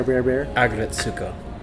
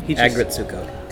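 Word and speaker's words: Aggretsuko: What's the pronunciation of that anime again? The angry red panda? – Huh? – Bear. – Agretsuko. – There's... Say it again. – Agretsuko.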